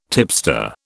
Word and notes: tipster: British English

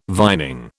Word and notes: vining: British English

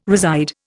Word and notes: reside: Indian

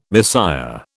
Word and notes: Messiah: British English

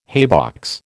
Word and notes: haybox: American English

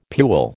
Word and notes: pule: American English

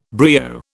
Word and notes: brio: British English

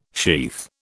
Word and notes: sheath: British English

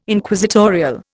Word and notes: Inquisitorial: Indian

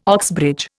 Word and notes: Oxbridge: Indian

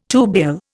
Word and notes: tubule: Indian

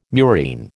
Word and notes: murine: American English

